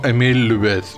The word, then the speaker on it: Émile Loubet: Y no solo estaba Alfonso XIII durante el atentado, sino también estaba el presidente de la República en aquel tiempo, Émile Loubet. ¿Lo he dicho bien? – Sí, perfecto.